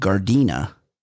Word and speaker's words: Gardena: He's a great listener, great fan of me, but it's a pretty funny turn of phrase, is when he mispronounces the city where Manuel is from, Gardena, as Gardenia.